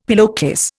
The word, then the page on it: pillowcase: Indian